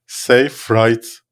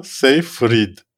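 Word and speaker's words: Seyfried: Telaffuz Amanda, Amanda Safe Right değil Amanda Safe Freed olacak.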